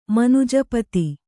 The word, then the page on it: ಮನುಜಪತಿ: kannada